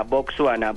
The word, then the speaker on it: Botsuana: En inglés se escribe así, con W después de la S, pero en español no lleva esa W, sino la U, tal como suena, Botswana, B-O-T-S. U-A-N-A.